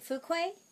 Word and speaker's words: Fuqua: Fuqua, who did a film that I love when you speak French.